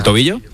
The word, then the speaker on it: tobillo: Cavilla. – ¿Tobillo? – Tobillo, pero nada, Nada grave.